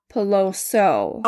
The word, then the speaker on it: Peloso: I was saying like Peloso and Pelosa for her last name. – It's Leviosa. – Oh my gosh. So I just wanted to make sure that I said it correctly because she is a victim. So it was Silvina Peloso.